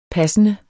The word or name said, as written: passende